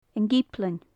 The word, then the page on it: an giblean: gaelic